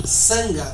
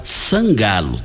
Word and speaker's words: Sangalo: A pronúncia é Sangalo? É, Zangalo? – Sangalo. – Sangalo.